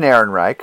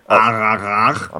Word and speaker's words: Ehrenreich: They've got Alden Ehrenreich, who's an up-and-coming young star. – Excuse me, Alden Ehrenreich. – I'm sorry, I always mispronounce his name. Alden Ehrenreich.